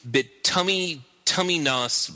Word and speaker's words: Bituminous: Oh my God, it's a real word. You have to pronounce it the real way. – Oh, or you might offend and upset Debbie. – Bituminous with a schwa.